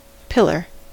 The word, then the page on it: pillar: American English